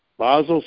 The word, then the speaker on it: Basel: Now, there are several levels of Basel compliance based on the Basel protocols, B-A-S-E-L, Basel, Switzerland, okay? And that's how it's pronounced in German. Basel.